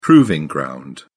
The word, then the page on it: proving ground: British English